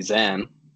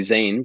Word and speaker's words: Zane: Yeah, so the funny thing about my name, I grew up, my parents would call me Zen. And I always used to correct everyone when they would call me Zane because it's spelled Zane.